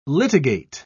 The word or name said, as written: litigate